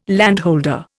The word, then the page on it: landholder: Indian